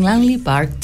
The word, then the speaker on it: Langley Park: Fue lanzada en su álbum de 1988, From Landry Park to Memphis. Ay, vos que sos traductora, ¿cómo se pronuncia bien? – From Langley Park to Memphis.